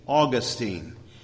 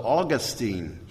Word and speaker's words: Augustine: I used to pronounce it for most of my ministry Augustine, but then we saw the ministry of Dr. Godfrey, who says his mother named him Augustino, so Augustine is how you would say it.